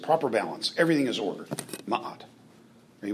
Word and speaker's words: ma'at: And in Egyptian, it meant ma'at. Well, that helped. Amen? Yeah. It's spelled M-A-A-T, ma'at. It's two syllables, ma'at. The word, among many other things, meant order. – What'd you say? – Order. O-R-D-E-R.